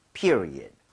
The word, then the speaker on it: period: period는 앞에 e에다 강세를 좀 많이 주시면 좋아요. – 네. – 그래서 같이 한번 해보도록 하겠습니다. period, period, period, period.